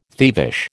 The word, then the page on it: thievish: American English